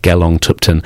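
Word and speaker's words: Gelong Thubten: My name is spelled GELONGTHUBTEN, Gelong Tupten.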